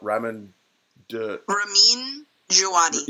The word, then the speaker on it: Ramin Djawadi: And I don't know how to say his name, but Ramin Djawadi. – Yes, that's how you say it. You said it last time.